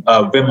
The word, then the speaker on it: Wim: But I often just try to remind myself, and I derived this from Wim Hof. It's W-I-M, and it's pronounced like with the V, like Wim, and then Hof, H-O-F.